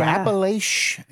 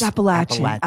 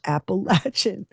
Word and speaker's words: Appalachian: I hate when people go Appalachian. – It's Appalachian. Appalachian. Here's an apple and a latch. Appalachian.